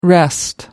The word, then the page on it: rest: American English